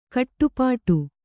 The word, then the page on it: ಖಟಿಪಿಟಿ: khaṭipiṭi